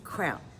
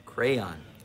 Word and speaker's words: crayon: Crayon. Two syllables. – Okay, this is an orange crayon. Crayon is how you say it.